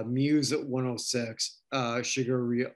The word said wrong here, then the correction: Sigur Rós: I never even know how to pronounce that. Shigeru's at 108. – That's Sigur Rós.